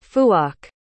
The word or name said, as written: phuoc